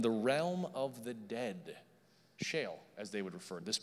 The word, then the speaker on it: Sheol: It's spelled S-H-E-O-L, pronounced Sheol, the realm of the dead.